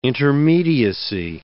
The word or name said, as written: intermediacy